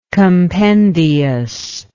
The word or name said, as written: compendiousness